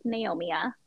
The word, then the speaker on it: Naomia: My personal Instagram, Naomia_May, and my name is spelled Naomia, so it's like Naomia.